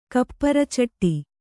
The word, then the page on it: ಕಪ್ಪರಚಟ್ಟಿ: kapparacaṭṭi